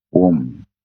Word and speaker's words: Om: And then prayers starts with the chanting of, you know, Om. Om jo syllable hai. – Right, sir. – Teen akshar ka A, O, N, M.